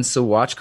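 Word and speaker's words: Saguache: I'm in Sewatch, Colorado today.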